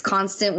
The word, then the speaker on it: Konstant: That's spelt with a K. That's important for everything when you're searching for me. It's Konstant with a K, K-O-N-S-T-A-N-T.